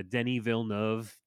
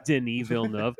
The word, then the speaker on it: Villeneuve: We're talking about Dune 2021, the Denis Villeneuve Dune movie. I made sure to say that because I Googled how to pronounce his name, and I'm very proud of myself. – Yeah. – Denis Villeneuve, starring Timothée Chalamet, which is also how you're supposed to say his name.